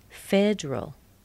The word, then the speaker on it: federal: Federal. The second E is missing. Not federal. Federal. General.